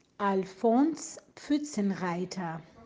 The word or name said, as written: Alfons Pfuetzenreiter